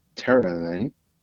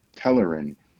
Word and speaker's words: Telerin: A, Talisca, B, Terelin, C, Sindarian. – Sindarian. – And B. – And let me amend. B is pronounced Telerin. Telerin.